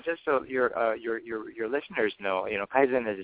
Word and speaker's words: Kaizen: With all due respect, it's pronounced Kaizen, Bob, so just so you know, and...